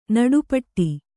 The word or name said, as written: ನಡೆಪಾಡು